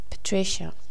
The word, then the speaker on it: Patricia: The second one is the name I actually use, Patricia. Patricia is P-A-T-R-I-C-I-A, Patricia. Let me repeat. P-A-T-R-I-C-I-A, Patricia.